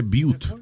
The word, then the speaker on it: Butte: dice, se pronuncia que Butte, pero se escribe Butt.